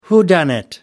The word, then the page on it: whodunnit: American English